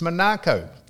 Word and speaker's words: Monaco: And I said, how do you pronounce he said, it's Monaco. It's an English, that's, and I said, well, why do people say Monaco?